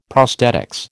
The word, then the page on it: prosthetics: American English